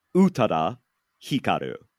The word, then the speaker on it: Utada Hikaru: Utada Hikaru is her name.